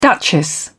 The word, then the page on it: duchess: British English